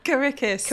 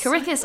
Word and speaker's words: Karikis: called For Many Voices by Mikhail Kakaris. I think I said it right. Did I? – Karikis. – Karikis. I said it again. – Mikhail Karikis. – Mikhail Karikis. We had this conversation just before recording and I still said it wrong, even after writing it down again. But hey ho. – Should do the phonetic. Oh. I've literally written K-A, the name Rick, and E-S.